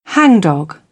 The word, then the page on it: hangdog: British English; ɒ